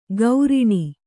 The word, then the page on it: ಗೌರಿಣಿ: Kannada